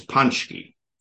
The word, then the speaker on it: Paczki: Paczki's are donuts originating in Poland, and they are pronounced a million different ways, but I have by good authority, because I'm married into a Polish family, that it's Paczki.